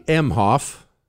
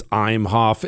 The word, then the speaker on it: Emhoff: So Doug Emhoff, that's how you say his name. It's Emhoff. It's E-M.